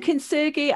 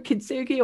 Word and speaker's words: kintsugi: what's kintsugi? And I've probably pronounced that incorrectly. Oh, kintsugi, I think you're right. I think we, we get different pronunciations depending on who's talking. um, kintsugi or kintsugi.